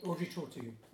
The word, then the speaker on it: Audrey Tautou: I really like Audrey Tautou. I don't know if that's how you say her name. – Audrey Tautou.